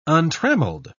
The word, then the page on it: untrammeled: British English